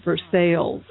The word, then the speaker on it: Versailles: I mean, it's not Versailles, it's Versailles Row.